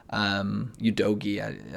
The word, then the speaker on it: Udogie: And then at Spurs, I thought Destiny Udogie looked pretty good at left back as well. Udogie, I might be saying that wrong. – I think that's right.